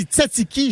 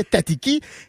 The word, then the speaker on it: tzatziki: La sauce tzatziki s'écrit avec les Z, fait qu'on fait, on dit tzatziki, jamais tatiki.